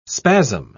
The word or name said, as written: spasm